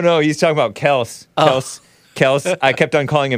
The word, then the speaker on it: Kelce: It's actually pronounced Kels and not Kelsey, says Big Bump about the guy.